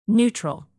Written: neutral

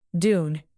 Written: dune

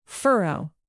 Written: furrow

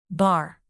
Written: bar

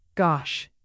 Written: gosh

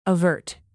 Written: avert

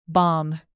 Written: bomb

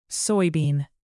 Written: soybean

